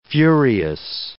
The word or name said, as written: furious